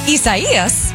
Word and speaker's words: Isaias: At least four dead as Isaias pushes farther inland across southeast.